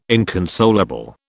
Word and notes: inconsolable: British English

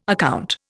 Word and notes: account: Indian

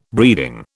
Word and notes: breeding: British English

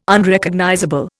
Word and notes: Unrecognizable: Indian

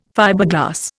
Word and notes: Fiberglass: Indian

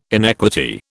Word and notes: inequity: British English

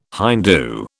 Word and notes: Hindoo: British English

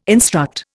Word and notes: instruct: Indian

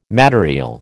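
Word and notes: matériel: American English

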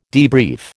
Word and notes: debrief: American English

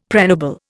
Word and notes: pregnable: Indian

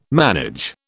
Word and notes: manage: British English

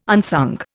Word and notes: unsung: Indian

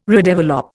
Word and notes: redevelop: Indian

